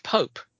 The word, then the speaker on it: Popp: And then going in, I think Mr. P-O-P-P is pronounced Pope from Machida.